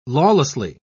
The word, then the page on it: lawlessly: British English